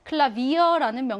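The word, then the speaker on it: Klavier: 클라비어라는 명사는요. 외래어예요. 외래어에서는 파우가 뭘로 발음 난다 그랬어? V로 발음 난다 그랬어.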